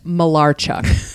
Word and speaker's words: Malarchuk: First thing he said was, it's pronounced Malarchuk.